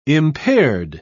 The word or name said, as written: impaired